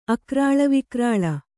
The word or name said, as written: ಅಕರಾಳವಿಕರಾಳ